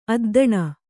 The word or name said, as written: ಅದ್ದನ